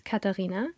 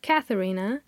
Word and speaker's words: Katharina: I have my German pronunciation of my first name, which is Katharina, and then I have the English version, which is Katharina, and then I have my very private one, my nickname Kathy, or Kati in German.